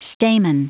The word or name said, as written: stamen